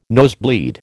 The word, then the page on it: nosebleed: American English